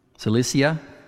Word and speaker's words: Cilicia: I don't think I said that right. Cilicia. Cilicia. Yeah, that's correct. That's better.